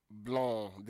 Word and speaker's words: blanc: Blanc. The C is silent. Blanc and blanche.